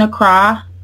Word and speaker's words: Accra: Were you in Accra the whole time? I'm not sure if I'm pronouncing that correctly, but, like, where in Ghana were you? – Yes, we were in Accra, which is the capital.